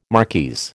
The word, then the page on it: marquise: American English